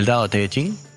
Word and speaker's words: Tao Te Ching: o lo voy a pronunciar en chino porque lo busqué, del Tao Te Ching, así se pronuncia, Tao Te Ching, como si fuera una pregunta, buenísimo.